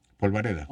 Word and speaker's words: polvareda: ¿Polvadera? ¿Cómo se dice? – Polvareda.